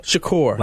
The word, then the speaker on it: Shakur: If you're on Twitter, am I saying that right, Jay Shakur? – Yes, that's fine. Yes. – No, that's fine. That means I didn't say it right. – It's Shakur. – Shakur. – Like Tupac. – Like Tupac Shakur.